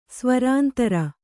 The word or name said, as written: ಸ್ವರಾಂತರ